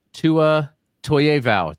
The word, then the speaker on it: Tua Toiaivao: Well, Coach Tua Toiaivao, did I carry the name pronunciation all the way through? – Perfect.